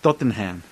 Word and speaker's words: Tottenham: Tottenham.